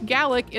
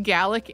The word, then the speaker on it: Gaelic: And also that Scottish Gaelic is pronounced Gaelic and not Gaelic, which is how I said it in the episode.